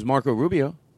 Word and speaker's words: Marco Rubio: Now that I think about it, Joy Behar said, "Yeah, you did when it was Marco Rubio."